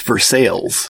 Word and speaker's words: Versailles: There's a town in Missouri named after the Royal Palace of Versailles, but locally it's pronounced Versailles.